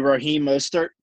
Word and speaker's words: Raheem Mostert: So my second sleeper will be Raheem Mostert.